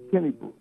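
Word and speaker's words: Kinebrew: K-I-N-E. – K-I-N-E-R-E-W. K-I-N-E-R-E. – No, K-I-N-E-B-R-E-W. B-R-E-W. – V-R-E-W. Pronounce it. – Kenny Brew.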